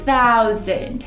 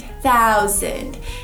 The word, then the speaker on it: thousand: Mil em inglês, thousand, thousand. Então minha língua fica entre os dentes e eu sopro no alto do céu da boca. Fica thousand, thousand.